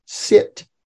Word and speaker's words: sit: The short I is pronounced I, eh, like in the English word sip. And the Latin word I gave you is the word sit, which is pronounced sit.